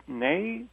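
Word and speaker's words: née: So Sylvia Kahn, Nay Rosenfeld. – Right. – Right. – You pronounced it Nay. Is it Nay? – Yes. Yes, it is. It's from French. And it simply means born in French.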